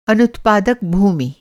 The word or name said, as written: अनुत्पादक